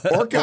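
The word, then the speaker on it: Orko: Now, the little wizard creature, Okra, did I get his name right? – No. – Orca. – Yeah. Okra's a little different, but similar.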